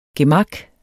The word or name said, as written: gemak